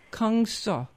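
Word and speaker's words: Kang Suh: I'm a child of the 80s in New York City, so my father, my parents always took us to Gangseo, right on Broadway. – Nice. Well, how do you spell that? – K-A-N-G and then S-U-H. – Gangseo.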